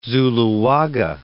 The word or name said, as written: Zuloaga